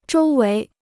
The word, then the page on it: 周围: zhōu wéi